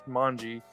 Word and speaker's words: Manje: It's called Manj, Manje. It's spelled M A N J E, and it's a voodoo Haitian word. It means to eat.